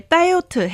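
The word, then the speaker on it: diet (다이어트): 只是韩文的那个 t 的发音都比较重而已。对，所以 diet 黑呀黑哦。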